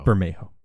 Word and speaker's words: Bermejo: Lee Bergemo did do the Oh, is that how you say his name? – Yeah. That's fine. – Bergemo. – Bermejo. That's okay.